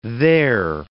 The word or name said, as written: there